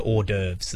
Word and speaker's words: hors d'oeuvres: Horse de Veres. It's French. Horse. That's not even a French say horse. Horse. – Horse. He's trying to get to hors d'oeuvres there. – Oh, it was hors d'oeuvres.